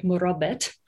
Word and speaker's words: murabit: At the moment in the region, there are figures who are called in French marabouts, right, which is based on a kind of French colonial mispronunciation of the Arabic murabit, but they are people who engage in these practices.